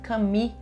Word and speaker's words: Camus: Vocês devem conhecê-lo porque ele era companheiro da Simone de Beauvoir e o Albert Camus. Né? Aqui fala Camus, em inglês fala Camus e escreve algo se fosse né,